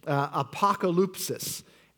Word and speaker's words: apokalypsis: apokalypsis.